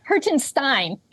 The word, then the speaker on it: Hertenstein: It's Hertenstein.